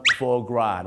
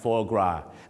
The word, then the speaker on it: foie gras: foie gras นะฮะ foie gras